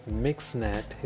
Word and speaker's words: MXNet: MXNet, pronounced as MixNet, is a deep learning framework supported by Python, R, C++, and Julia.